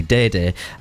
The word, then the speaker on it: Dai Dai: Now, it's spelled D-A-I-D-A-I. Now, I think... That is basically Day Day.